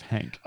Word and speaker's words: Henk: Hank sounds um, like it's a mispronunciation of Henk.